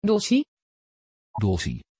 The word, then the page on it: Dolcie: Dutch